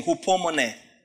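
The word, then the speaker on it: hupomone (ὑπομονή): Same thing, hupomone. Same word. As it is pronounced. Hu or hu, po, mo, ne. E with the accent.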